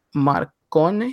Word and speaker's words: Marcone: You have the likes of Guido Rodriguez going up against the likes of Ivan Marcone. Is that how you say his name? – Marcone. That's how I say it, yeah.